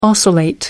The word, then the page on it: oscillate: American English